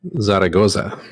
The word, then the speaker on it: Zaragoza: Zaragoza.